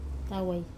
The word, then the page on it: tawai: Wamesa